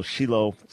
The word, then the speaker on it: Shiloh: Shiloh is the proper Hebrew pronunciation, and he is the founder and president of the Shiloh Israel Children's Fund.